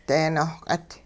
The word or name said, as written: dee-nohq'it